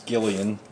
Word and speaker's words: Gillian: Jillian Jacobs is... – Gillian. – Is it Gillian? – Yeah. – Like she's, it's spelled Jillian, but pronounced Gillian.